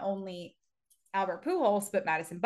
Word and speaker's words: Pujols: It says Pujols. – It says Pujols. – Dash holes.